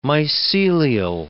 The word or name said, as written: mycelial